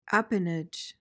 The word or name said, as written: appanage